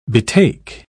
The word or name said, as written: betake